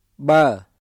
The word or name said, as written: បឺរ